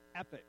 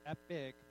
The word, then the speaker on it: epoch: One of them has to do with like epochs, like this, not epic, but epoch. Epoch? How do you say that word? I don't know. E-P-O-C-H. I did spell that right and I wasn't looking at it.